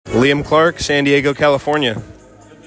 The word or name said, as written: Liam Clark